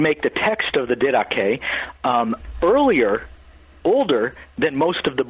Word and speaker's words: Didache: Well, the Didache, that's spelled D-I-D-A-C-H-E, the Didache is